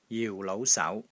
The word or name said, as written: JIU4 LOU5 SAU2